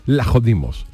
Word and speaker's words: Vlachodimos: Vlacho, se escribe Vlachodimos, Vlachodimos, sí, vale, pero Jesús dice que la pronunciación de Vlachodimos es Lajodimos. Griego, porque claro, la V de la S pierde, Lacho es Lajo.